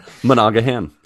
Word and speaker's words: Monaghan: And then Cameron Monaghan. Oh, you don't pronounce the G. – Monaghan. – Maybe Manamana. – Monaghan.